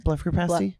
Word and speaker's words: blepharoplasty: A blepharoplasty. Is that how you say it? Blepharoplasty? – Blepharoplasty.